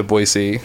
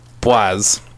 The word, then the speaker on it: Boise: That was the best thing that happened to Boise.